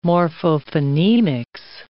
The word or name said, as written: morphophonemics